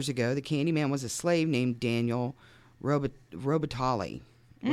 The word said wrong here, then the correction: Robitaille: which I'm sure is not how you say his name. Robitaille, Robitaille.